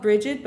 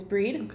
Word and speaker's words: Brigid: And in, uh, in Irish Celtic tradition, this holiday is dedicated to the goddess Breed, also called Brigid, but the correct pronunciation is Breed.